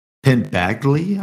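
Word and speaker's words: Penn Badgley: Penn Bagley, I believe is how you say his name.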